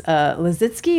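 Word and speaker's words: Lissitzky: Murphy likes Lisitsky. Is that how you say it? – Yes.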